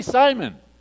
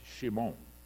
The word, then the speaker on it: Shimon: I want to be Simon. He didn't know that it's pronounced Shimon.